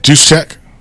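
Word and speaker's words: Juszczyk: How do you say it? – Juice check. – Is that right, or are you just saying that? – I think that's right.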